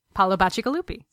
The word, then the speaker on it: Paolo Bacigalupi: Paolo Bacigalupi.